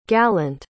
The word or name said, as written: gallant